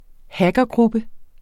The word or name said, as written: hackergruppe